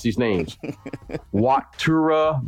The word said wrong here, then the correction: Wataru: All right, it's Calvin Murphy, Wataru.